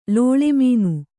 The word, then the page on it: ಲುಳಿಮನ: luḷi mana